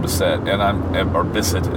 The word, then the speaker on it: Bisset: Well, he's there with the stewardess, this younger stewardess who he's having an affair with, played by Jacqueline Bisset, as it's actually pronounced.